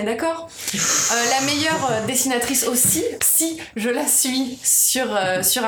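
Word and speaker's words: Cy: Alors, les Radium Girls, c'est une BD de Si, à Cassiriel, donc qui ne se prononce pas Sai, comme je l'ai entendu tout à l'heure.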